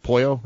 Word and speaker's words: Pollo: Well, somebody said Pollo Tropical, I think is how you say it. Pollo, right? – Pollo.